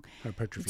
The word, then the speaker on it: hypertrophy: You do want hypertrophy. – Yes. – I always say that wrong. – Hypertrophy. – It's what you want.